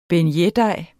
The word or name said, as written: beignetdej